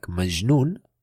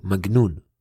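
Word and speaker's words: majnun (مجنون): Or actually in Arabic, they use it as a soft G, like majnun, crazy, but the Egyptians, they always say g, magnun, alright?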